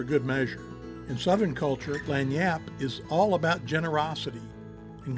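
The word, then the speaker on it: lagniappe: Today we're diving into the concept of what's called Lanyap. Lanyap, pronounced as Lanyap, is a Creole term that means an extra or unexpected gift.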